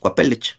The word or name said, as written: kuapelech